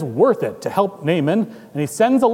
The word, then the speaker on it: Naaman: Naaman, although his name would have been pronounced Naaman, I'm going to say Naaman for general reasons.